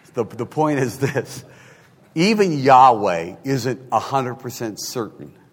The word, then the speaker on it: Yahweh: He goes, in spite of the uncertainties that exist, there is now strong scholarly consensus that the original pronunciation of the Tetragrammaton is Yahweh. So YHWH with the correct vowels would say Yahweh.